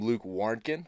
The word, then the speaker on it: Luke Warnken: Yeah, today we welcome Luke Warnken.